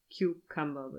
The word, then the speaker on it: Cucumber: Ich habe es inzwischen nachgeschaut, gehört und auch in der Serie Cucumber wird es ausgesprochen.